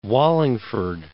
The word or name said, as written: Wallingford